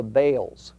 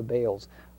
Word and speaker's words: Baal: I understand that the more technical pronunciation, probably more accurate, is Baal. Okay, but the more common use pronunciation is Baal, and that's even what the dictionary has as the pronunciation. So for our purposes, we'll use the Baals.